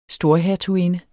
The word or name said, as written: storhertuginde